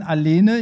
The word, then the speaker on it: Alene: Okay, die nächste Nummer, und zwar Israel, Eden Alene. Ich hoffe, ich habe das richtig ausgesprochen. Spricht man das eh am Ende mit? – Das ist richtig. Ich glaube, ja.